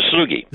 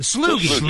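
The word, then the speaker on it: Sloughi: Is it the Slowey? The Slowey? – The Sloogie. – The Sloogie. Excuse me.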